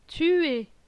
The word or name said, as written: Tuer